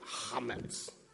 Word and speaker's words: chametz: And so one of the things that you do for Passover is clean your house out of what's called chametz, which is all the anything that's leavened. – You can say it like chametz. – Yes, that too.